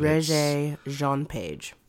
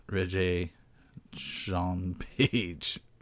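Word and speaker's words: Regé-Jean Page: And I know that it made many a fan of the actor who played Simon, whose name I always have trouble pronouncing, but Regé-Jean-Page. – There you go. Regé-Jean-Page. Perfect.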